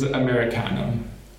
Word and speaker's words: americanum: I think I said that correctly now, Matt, if you're listening. One of our colleagues was giving me shit for this a little while ago. It is Americanum.